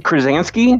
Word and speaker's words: Krasinski: We have John Krasinski.